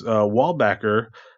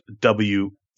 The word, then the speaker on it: Weilbaecher: That's Weilbaecher. Spelled very different than how it's pronounced, Weilbaecher.